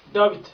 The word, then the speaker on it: Dawit: David.